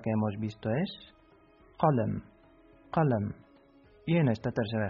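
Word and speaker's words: qalam (قلم): Se pronuncia qalam.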